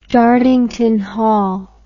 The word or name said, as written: Dartington Hall